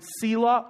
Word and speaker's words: Selah: It's spelled S-E-L-A-H. You could pronounce it Selah or Selah, either way.